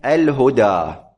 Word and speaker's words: الهدى: مثال الثانية الهدى، الهدى لا تكتب الألف ماذا فيها؟ ألفا، آخرها ألف، أنت تلفظ ألفا، تقول الهدى، تلفظ ألفا، لكنك لا تكتبها ماذا؟ ألفا، وإنما تكتبها ياء، تكتبها بصورة الياء، طبعا من دون نقاط، من دون نقاط.